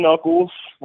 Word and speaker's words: Nuckolls: Well, my real name is Stephen Knuckles. It's not spelled the same as knuckles on your hand, but it's pronounced the same.